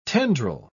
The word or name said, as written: tendril